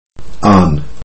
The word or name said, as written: on